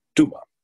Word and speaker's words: Dumas: I'm tempted to pronounce it the way my little brother always used to like to pronounce it in school to incur the wrath of his teachers, but it's Dumas.